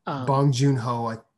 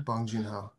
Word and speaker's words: Bong Joon-ho: I hope I said his name right. – Bong Joon-ho. Yeah, that's it. Yeah. Bong Joon-ho.